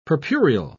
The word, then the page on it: purpureal: British English